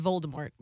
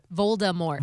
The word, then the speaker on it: Voldemort: There's no R. It's Voldemort. – Wait, what? – Voldemort. – Voldemort, yes. Thank you.